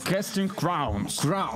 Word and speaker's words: Casting Crowns: Casting Crowns у нас, в принципі, правильно я вимовив? – Casting Crowns.